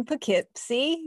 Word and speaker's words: Poughkeepsie: He was a professor of counseling at the College of New Rochelle and lives in Poughkeepsie, yes, I know how to pronounce it.